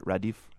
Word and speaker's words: Radif: Now, the style of music you're known for is called Radif. Am I pronouncing that right? Radif? – Correct.